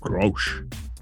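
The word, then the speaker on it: Kölsch: Grosch, Klosch beer. – I've never known how to pronounce it. K-O-L-S-C-H. Klosch? – Grosch. – With the German accent. Love it.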